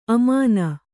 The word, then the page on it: ಅಮಾನ: amāna